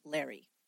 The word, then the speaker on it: Lauri: Now, if you've ever seen anything written about Larry, his name is spelled L-A-U-R-I. It looks like Lori, but the accounts that I found said everybody pronounced it Larry. Eileen, as you might know, is also spelled a little bit differently. It's A-I-L-E-E-N, when usually we spell it with an E.